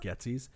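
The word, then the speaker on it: Goetze's: I think that's how you say Getzy's. Goetze. – It's probably how I would pronounce it.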